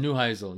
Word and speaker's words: Neuheisel: I don't know how to say this name, but we'll say Rick Neuheisel. – Neuheisel used to be the coach at UCLA.